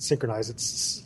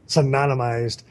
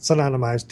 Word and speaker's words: synonymized: So, the word I mispronounced there was not synchronized, I hate this word. – Synonymized. – It sounds like this. Synonymized.